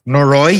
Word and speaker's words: Noroi: Ooh, what did I see that I want to say, I'm going to mispronounce it, Noroi, The Curse. – Noroi, yeah, so Noroi. Good.